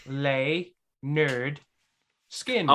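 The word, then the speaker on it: Lynyrd Skynyrd: If it was Skinnard, it'd be S-K-I-N-A-R-D. – That's exactly how it's spelled. – S-K-Y. – The album Free Bird is on is called Pronounced Lay Nerd Skin.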